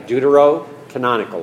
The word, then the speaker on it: Deuterocanonical: Okay? Deuterocanonical. Like Deuteronomy? Deuterocanonical.